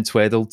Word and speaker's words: Tweddle: So Mark with a K and then Tweddle, TWEDDLE.